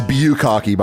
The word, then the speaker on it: bukkake: it's pronounced Bukkake, by the way.